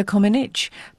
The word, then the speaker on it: Comăneci: Which apparently most of us have been pronouncing wrong for the past 50 years. – Oh, tell me what is the right way. – Well, no, you did it correctly. – Oh. – It's Nadia Comăneci.